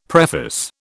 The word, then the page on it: preface: British English